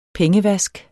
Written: pengevask

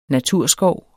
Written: naturskov

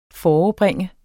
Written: forebringe

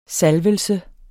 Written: salvelse

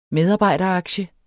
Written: medarbejderaktie

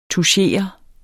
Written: tuschere

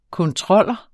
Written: kontroller

